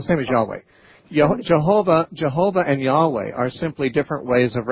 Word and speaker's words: Yahweh: Yahweh is one way to pronounce it. Jehovah is another way to pronounce it, but Yahweh is closer to the Hebrew way.